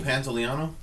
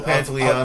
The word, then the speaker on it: Pantoliano: Joe Pantoliano? – Thank you. – Joe Pantoliano.